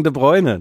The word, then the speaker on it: De Bruyne: Wegen De Bruyne, der alte Witz. – Wegen De Bruyne. Das ist doch, ich habe es niederländisch ausgesprochen.